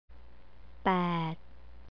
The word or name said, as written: pad